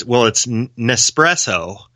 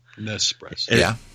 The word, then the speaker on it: Nespresso: But, actually, I put a note in here because it's – I couldn't pronounce it well. It's Nespresso. – Nespresso.